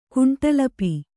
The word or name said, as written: ಕುಂಟಲಪಿ